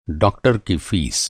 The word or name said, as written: फ़ीस